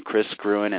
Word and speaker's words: Kris Gruen: And I'll just remind folks of my name, Kris Gruen. It's spelled with a K, K-R-I-S, and last name is G-R-U-E-N.